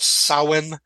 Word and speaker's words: Samhain: And we talked a little bit about this, the fact that on Halloween, the spirit Samhain, it looks like it's written Samhain, yeah. – but it's pronounced Samhain.